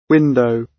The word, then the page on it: window: British English